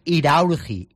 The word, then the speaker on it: Iraurgi: Bueno, me corrige Alberto Marco que lo he dicho mal, no es Irurgi, que he dicho Irurgi, es Iraurgi, de Azpeitia, de la localidad vasca.